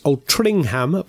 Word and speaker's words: Altrincham: It's an odd one for the pronunciation police, as it's pronounced Altrincham, but spelt A-L-T-R-I-N-C-H-A-M.